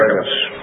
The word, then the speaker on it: Archippus: So, can I ask you, dear, this, how do you pronounce it? Archippus? How do they say it in the Bible? Archippus.